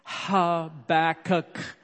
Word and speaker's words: Habakkuk: And so I'd like for you to pronounce that out loud with me with emphasis on the first and last letter, okay? Habakkuk.